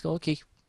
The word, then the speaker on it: Orci: But also, while we're on the subject of Robert Orci, I think it's Orci. It's spelt that way.